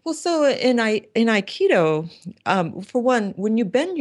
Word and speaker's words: Aikido: Can you sort of relate that to us, not only in the, and I know I said it wrong to begin with, what's the correct way to say it? Aikido? – Aikido.